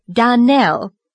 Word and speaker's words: Donnell: Donnell, accent on last syllable, and St. Clair.